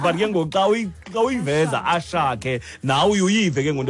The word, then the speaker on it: Asake: So the S is Sh. – Oh. – Ashake, not Asake.